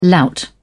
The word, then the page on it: lout: British English